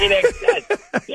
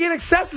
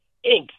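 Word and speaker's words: INXS: I don't think in excess is in the Hall of Fame either. – I don't know either. I don't know either, but I know they weren't pronounced Inks.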